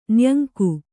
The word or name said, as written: ನಾಯಂಕ